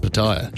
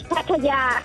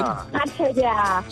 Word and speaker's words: Pattaya: The correct pronunciation is Pattaya. – Hang on. Can she say that again? – Pattaya. – So there's a long ya at the end.